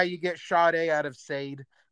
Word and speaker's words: Sade: But it's like, what is it, that musician, Sade or something like that, and her name is spelled S-A-D-E, like Sade or Sadie, but it's Sade.